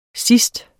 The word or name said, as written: sidst